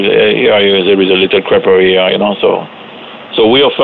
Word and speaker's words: crêperie: But you also have a traditional French crêperie. Did I say that right? – Yeah, you said it right.